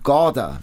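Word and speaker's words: Gaarder: Und es geht um Sophies Welt von Jostein Gorder.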